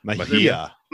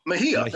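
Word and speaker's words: Mejía: But you said how do you pronounce it? Me, Miha, whatever it is. – Mahia. Mahia. Mahia they're both going to get a start in against Baltimore.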